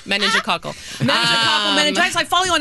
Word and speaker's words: meningococcal: Meningococcal meningitis.